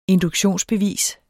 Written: induktionsbevis

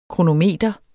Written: kronometer

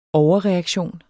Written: overreaktion

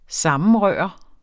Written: sammenrøre

